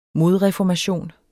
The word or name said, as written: modreformation